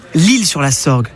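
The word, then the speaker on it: L'Isle-sur-la-Sorgue: Ah, notez bien qu'on dit l'île sur la Sorgue, mais que l'île s'écrit I-S-L-E.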